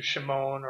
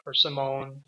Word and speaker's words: Shimon: Okay, we say it's Simon. That would be an anglicized form of the name. The Hebrew pronunciation would be something more akin to Shimon or Simone.